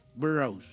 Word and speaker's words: Burroughs: B-U-R-R-O-U-G-H-S. – The Burrows. Oh, the Burrows. – Burrows. – ¿Qué significa burrows? – Burros es como las ajueras, ¿no? ¿Como las ajueras o qué será? Burrows es como... – Burrows es...